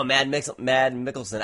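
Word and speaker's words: Mads Mikkelsen: It has Mads Milk. How do you say it? From Hannibal? – Oh, Mad Mickelson.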